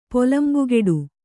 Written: ಪೊಲಂಬುಗೆಡು

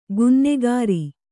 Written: ಕನ್ನಾಗರು

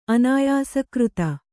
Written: ಅನಾಯಾಸಕೃತ

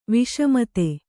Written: ವಸುಮದ

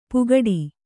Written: ಪುಗಡಿ

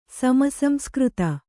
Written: ಸಮಸಂಸ್ಕøತ

